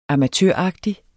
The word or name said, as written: amatøragtig